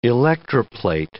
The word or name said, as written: electroplate